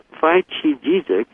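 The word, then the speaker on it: Phyqigesic: Now, Phi-Chi-Jizik, and it's spelled with Q-I in the middle, so it's P-H-Y-Q-I-G-E-S-I-C,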